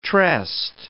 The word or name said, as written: tressed